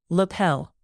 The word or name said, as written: lapel